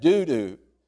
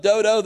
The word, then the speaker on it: Dodo: You can pronounce that doo-doo, but either way, it's not a good name. But he overcame his name. After him was Eleazar, the son of Dodo, the Ahohite, one of the three mighty men when they defied the Philistines who were gathered there for battle.